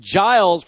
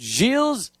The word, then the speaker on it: Gilles: We've been calling him Giles for 4 years or 3 years, however long it is, but it's good to know that Gilles DeConnick is the correct pronunciation of his name.